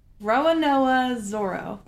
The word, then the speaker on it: Roronoa Zoro: Roronoa Zoro.